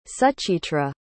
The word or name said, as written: suchitra